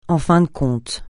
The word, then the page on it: en fin de compte: français